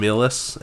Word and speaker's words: Milius: John Millis, M-I-L-I-U-S, M-I-L-I-U-S.